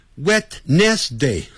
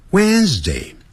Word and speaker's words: Wednesday: 那礼拜三呢，我们 It's 先不念， Wednesday，那个 WD 那个 D 不念出来的 When，更不要念成 Wednesday、哦、不是按照那个字母这样来念啊，不要自己发明叫 Wednesday，不是这样子。 Wednesday Wednesday 有声的 z 啊， Wednesday Wednesday Wednesday today あ、礼拜四呢 Thursday Thursday 那个舌头先吐出来，然后很快往内缩。